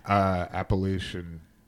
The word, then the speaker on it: Appalachian: Appalachian.